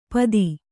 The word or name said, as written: ಪತಿ